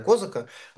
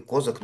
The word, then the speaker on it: Козак: Сейчас как будто бы надежды совершенно нет, но вышло вот расследование, несколько расследований про Дмитрия Козака, благодаря которым мы понимаем, что и вокруг Путина могут возникнуть люди, которые попытаются схватить его за руку. Вкратце людям напомню, что Дмитрий Козак, некоторые ставят ударение как казак,